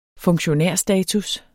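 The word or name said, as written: funktionærstatus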